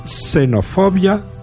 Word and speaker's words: xenofobia: Y así digo extensión, examen, pero ha de pronunciarse simplemente S si va al comienzo de la palabra. Digamos, por lo tanto, xenofobia o xilófono, aunque se escriban con X.